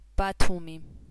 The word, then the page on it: ბათუმი: [ˈbatʰumi]; Georgian